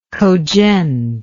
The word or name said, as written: Khodzhent